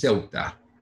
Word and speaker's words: Ceuta: Help me again with the pronunciation. It is Ceuta. – Spelled C-E-U-T-A. Ceuta.